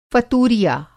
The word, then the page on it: फ़तूरिया: Hindi